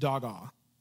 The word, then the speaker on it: hagah: The Hebrew word for meditate is hagah, pronounced dagah, H-A-G-A-H, and it means to moan, growl, utter, or muse.